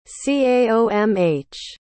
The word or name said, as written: caomh